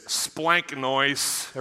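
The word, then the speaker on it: splanknois (σπλάγχνοις): It's splanknois. Everybody try splanknois. Splanknois. You got to say it like that. It's kind of nasal.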